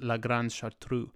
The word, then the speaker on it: La Grande Chartreuse: And Brother Vincent lived at this monastery, a famous Carthusian monastery up in the French Alps called Le Grand Chartreuse, or it's, I think, technically pronounced La Grande Chartreuse.